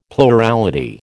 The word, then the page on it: plurality: American English